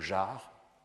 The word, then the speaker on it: jars: Vous avez aussi le mot jar, J-A-R-S, dans lequel le R se prononce mais pas le S.